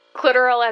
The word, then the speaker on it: clitoral: Like clitoral as in rhyming with literal.